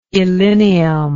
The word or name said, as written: illinium